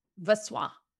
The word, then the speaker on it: Voisin: Voisin. Voisin. – When in doubt, just say it like croissant.